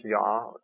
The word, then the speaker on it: Jah: And in fact, Hallelujah has J-A-H, Yah, at the end. Pronounce Yah, Jehovah is really how you'd pronounce it if it was in its full form.